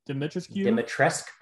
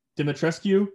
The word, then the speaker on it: Dimitrescu: Dimitrescu, I think. – Dimitrescu, yeah, that makes more sense.